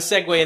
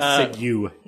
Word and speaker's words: segue: No, I have a segue in the other sense of the word. – A segue. – Thank you. I mispronounced it as usual. A segue.